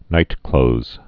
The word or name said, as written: nightclothes